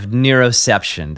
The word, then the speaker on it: neuroception: And the term was autonomic surveillance system of neuroception. Did I say that right? You did. Good job.